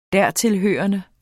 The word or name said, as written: dertilhørende